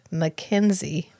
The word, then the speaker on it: Mackenzie: In our current game, I play a elf bard named Mackenzie with 2 E's. The last E is silent.